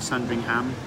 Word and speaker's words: Sandringham: So had the privilege in going along to Hodges in Sandringham, or Sandringham as they like to pronounce it here, and spent for the 8:30 sales meeting.